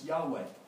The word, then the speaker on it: Yahweh: And so all the Hebrew nerds today, the Hebrew scholars, will tell us it's pronounced Yahweh, is how you pronounce it.